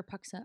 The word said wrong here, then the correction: Punxsutawney: I think I'm saying it right. Yeah, Punxsutawney.